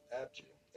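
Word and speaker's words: Abdju: The site of Abydos is pronounced Abju in Egyptian, which means the reliquary hill.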